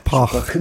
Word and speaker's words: Park: Hát az erbetűt azt nem kell ejteni, a chan Wookban nem vagyok biztos, de hogy Park, Park. Sokak. – Pak.